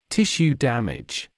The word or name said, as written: tissue damage